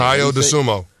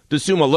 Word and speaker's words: Dosunmu: Ayo Dosunmu. – Yeah, Dosunmu.